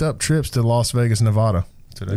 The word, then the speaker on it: Nevada: I mean, I don't claim to be the smartest man on this planet, but I know how to say Good. Nevada. – Yeah, me too. I just said it like four times, Nevada.